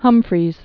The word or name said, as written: Humphreys Peak